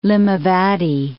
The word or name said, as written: Limavady